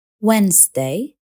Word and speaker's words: Wednesday: الحرف الصامت D هو الأكثر شيوعا في كلمات Wednesday